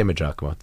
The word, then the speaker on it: Maggiacomo: So we say Maggiacomo. The name James in Italian is Giacomo, so it's like easier.